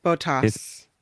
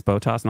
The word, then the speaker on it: Bottas: Now, his name is pronounced or is spelled B-O-T-T-A-S. – Botas. – It's Botas, not Botas. Botas.